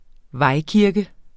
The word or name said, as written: vejkirke